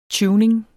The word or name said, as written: tuning